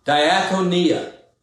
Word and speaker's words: diakonia: The word translated into English as servant is the Greek noun diakonia. Diakonia. Now, it's spelled D-I-A-K-O-N-I-A. Diakonia, one of the most important words in the New Testament.